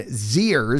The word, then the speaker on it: Zers: Nearly 400,000 Generation Zers. Is that how you say it? Zers? Yeah, right, because Generation Xers, so Generation Zers, stopped living on their own.